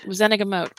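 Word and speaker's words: Wizengamot: I'm gonna go with Wizenagemote because Wizenagemote because that Wizenagemote is based on Witenagemote, so it, I'm assuming the pronunciation should be the same.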